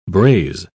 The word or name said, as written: braze